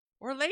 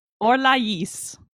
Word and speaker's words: Orlais: They don't say Orlais when they're speaking French. – What do they say? – Say Orlais, and they pronounce the S. – What? – I don't know why. – What? – They have an accent over the I to make sure you pronounce the S at the end.